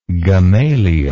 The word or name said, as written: gamaliel